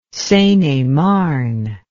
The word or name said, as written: seine et marne